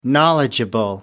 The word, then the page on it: knowledgeable: American English